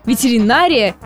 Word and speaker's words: ветеринария: Оказывается, пришедшее к нам из латыни слово сохранило свое ударение на четвертом слоге. И хоть мы и привыкли говорить «ветеринария», придется привыкать по новой. Ветеринария и не иначе.